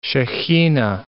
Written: Shechinah

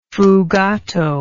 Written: fugato

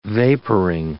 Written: vaporing

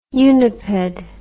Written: uniped

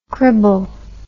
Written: cribble

